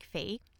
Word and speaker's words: Phi: Yes, my name is spelt P-H-I and you say it like Fee.